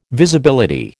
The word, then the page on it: Visibility: American English